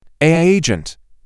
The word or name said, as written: AI agent